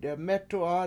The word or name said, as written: demEtu7a